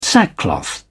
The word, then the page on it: sackcloth: British English